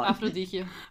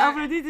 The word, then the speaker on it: Aphrodite: Afroditje. – Aphrodite. – Ja, wat is dat ook alweer? Nee, Aphrodite is de godin.